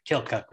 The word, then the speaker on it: Keokuk: They pronounce it Keokuk there.